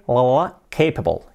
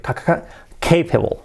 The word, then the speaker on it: capable: Lo primero, la pronunciación, que no es, se escribe capable, no es capable, cuidado la L, capable, y la C fuerte, capable, ¿ok?